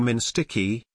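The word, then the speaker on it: MNSTC-I: It was known as MNSDCI, which the military, in a Freudian moment, began pronouncing Minsticky, as if it were the Ministry of Intractable Problems, which it effectively was.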